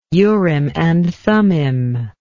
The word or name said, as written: Urim and Thummim